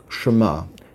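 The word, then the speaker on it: Shema (שמה): Now, the root word of Shemayim is a word pronounced Shema. It's spelled Shin Mem He.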